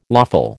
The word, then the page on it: lawful: American English